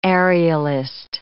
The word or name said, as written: aerialist